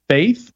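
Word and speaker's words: F8th: I think the one is supposed to be pronounced Faith, but it's spelled F, the number 8, and then TH.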